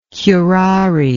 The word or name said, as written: curare